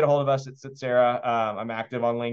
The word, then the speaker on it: Tsetserra: Again, we're active in Texas buying businesses, but you can get a hold of us at Setsera. It's spelled T-S-E-T-S-E-R-R-A.